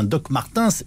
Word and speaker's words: Doc Martens: Je précise qu'on prononce bien Doc Martens historiquement, nous sommes d'accord.